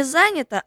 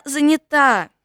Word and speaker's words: занята: Мама не занята, а занята. Все запомнили? Занята.